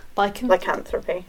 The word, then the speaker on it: lycanthropy: A person accused of witchcraft might also be accused of lycanthropy, or am I saying that right? – Lycanthropy. – Lycanthropy. Words are so hard.